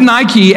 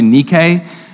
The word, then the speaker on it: Nike: The, the word Nike actually means, well, let me just say, I think the Greek pronunciation, although I'm not Greek, I think the Greek pronunciation is closer to Nike but that doesn't sound as trendy as, as Nike does.